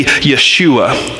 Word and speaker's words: Yeshua: And the name Jesus, as we pronounce it Jesus in English, if we were to say it in Hebrew, would actually be Yeshua.